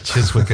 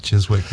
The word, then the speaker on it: Chiswick: Chiswick is not Chiswick, yes. Like so many English place names. – Yes. – There's letters in there that don't get used.